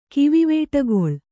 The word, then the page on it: ಕಿವಿವೇಟಗೊಳ್: Kannada